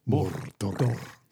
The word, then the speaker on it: Mordor: Also, I'm impressed with the pronunciation of the word Mordor. – Mordor. – It's not easy, is it? – Yeah. – I had to practice that. – You can't roll your R's, you've got no chance.